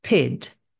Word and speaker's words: pyt: It's pronounced pid and spelled P-Y-T.